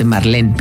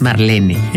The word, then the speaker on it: Marlene: que todo el mundo dice Marlene, pero es Marlene.